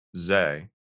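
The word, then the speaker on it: Tse: The new Police Story from 2004, which new also stars Nicholas Zay. I believe that's how you pronounce his last name. I looked it up and I kind of forgot it, but I'm pretty sure it's Zay, who stars alongside Jackie Chan, and he also stars alongside Donnie Yen in this film.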